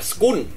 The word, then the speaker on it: school: Ví dụ như từ ngôi trường đi, ngôi trường nó có một cái này khó nè, là nó không phải là school mà nó là school, school ha, school, rồi xong cuối là có cái L, school ở cuối là L chứ không phải là N.